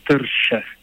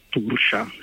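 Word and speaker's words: Tuirse: Allora, gaelico irlandese è come giustamente dici, la pronuncia corretta, senza pretendere peraltro di averla nemmeno io, però sarebbe Tersche. – Ah, ok, Tersche, okay. – Tersche o Tursha, se vogliamo latinizzarlo un pochino, sì, perché appunto quello che si scrive non è decisamente quello che si legge in gaelico.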